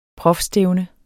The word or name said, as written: prof.